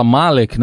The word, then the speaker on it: Malek: Que na verdade a gente fala Malek, né? Mas, é tudo bem, eu, vou, eu tô acompanhando a pronúncia mundial, mas ela, eh, em árabe a gente fala okay. Malek.